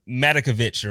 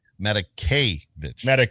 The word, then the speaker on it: Matakevich: My guy, Tyler Matakovich, or however you say his name, I always mess it up. – Matakevich.